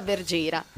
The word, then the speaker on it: Bergera: si pronuncia Bergera, che ecco, vuol dire pastorella.